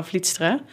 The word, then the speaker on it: Vlietstra: Should I say it in Dutch? Because I always struggle, especially my last name is difficult to pronounce in English. So I'm Lara Vlietstra.